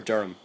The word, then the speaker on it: Durham: Am I saying that correctly? Durham or Durham? – Durham.